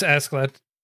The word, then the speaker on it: Askeladd: But in here, I feel like Ashland, is that? I never get his name right. – It's Askeladd. – Askeladd, sorry. He saw the potential that he had,